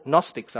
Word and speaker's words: Gnostics: They were called Gnostics. I've got a word. It's a silent G.